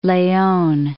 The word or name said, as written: Nuevo Leon